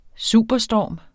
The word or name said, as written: superstorm